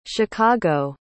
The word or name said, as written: chicago